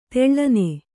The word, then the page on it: ತೆಳ್ಳನೆ: Kannada